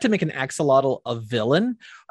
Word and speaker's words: axolotl: I will say that the last character on my my stretch goal list is I'm going to say it wrong. A, a lox a lox a oh, uh, axolotl. Uh, axolotl. Axolotl. Axolotl.